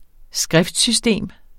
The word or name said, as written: skriftsystem